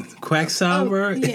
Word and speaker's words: quacksalver: Quacksalver. – And bite the bullet. Okay, on to the acronym of the week. – Oh my god, you finally said it right.